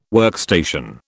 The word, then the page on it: workstation: British English